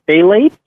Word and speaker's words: phthalates: So dryer sheets contain these things called phthalates, which is spelled with a PTH as a nice silent P at the beginning.